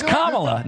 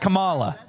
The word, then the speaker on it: Kamala: It's Kamala, not Kamala. Kamala Harris, not Kamala. You put the emphasis on the wrong syllables, so you're a racist.